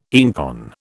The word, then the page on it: einkorn: British English